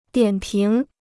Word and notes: 点评: diǎn píng

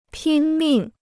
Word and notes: 拼命: pīn mìng

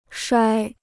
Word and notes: 摔: shuāi

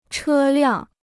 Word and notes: 车辆: chē liàng

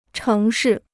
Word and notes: 城市: chéng shì

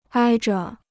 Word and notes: 挨着: āi zhe

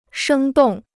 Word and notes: 生动: shēng dòng

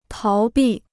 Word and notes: 逃避: táo bì

